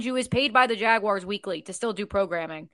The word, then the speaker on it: Jaguars: I would say the Jaguars.